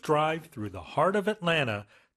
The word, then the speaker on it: Atlanta: And pronunciation, usually it's just pronounced Atlanta, right? Instead of Atlanta. – 그렇죠. 이건 한국식 발음이니까. – Yeah. – 네. Atlanta, 뭐 미국식으로 말 Atlanta. 그래서 세 개의 음절이에요. 우리는 Atlanta, 사음절로 한국식으로 하지만 영어로는 삼음절.